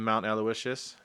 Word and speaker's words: Mount Aloysius: On the women's side, played a solid team in Mount Aloysius. I think I know how to say it finally. – You got it.